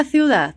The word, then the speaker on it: ciudad: En el centro de la ciudad. Fala você agora. Cuida dessa pronúncia aí. Tem um U na palavra cidade, é? Ciudad.